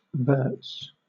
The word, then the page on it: verts: British English